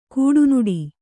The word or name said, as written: ಕಡುನುಡಿ